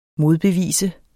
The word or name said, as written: modbevis